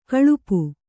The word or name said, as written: ಗಳಪು